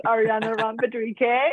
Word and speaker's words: Ariana Ron Pedrique: Like you said and pronounced perfectly, my name is Ariana Ron Pedrique.